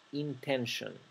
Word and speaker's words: intention: Fíjense que la terminación T-I-O-N no se pronuncia tion, se pronuncia shon, shon, intention. Así que nunca lo pronuncien intention, es mi intention, no es una H. Se pronuncia como una sh.